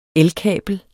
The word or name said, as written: elkabel